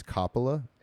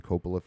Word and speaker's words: Coppola: Actually, the correct way to pronounce his last name Coppola is Coppola, and uh, I just found that out, and I've been saying Coppola for years.